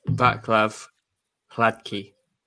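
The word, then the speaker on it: Václav Hladký: Vaclav Halanki? That's probably not. No. – Halanki. – K's fine. We're just missing a D in there, I think. – Yeah. – You're obviously officially the show's best pronouncer of names, particularly Eastern European names. So how should we refer to Town's new number one? What's his name? – It's Vaclav Hladky.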